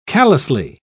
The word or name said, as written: callously